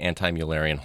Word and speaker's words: anti-Müllerian: So why don't you tell us what exactly is the anti-Müllerian hormone? – First, good job in pronouncing it perfectly.